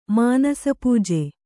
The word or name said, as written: ಮಾನಸಪೂಜೆ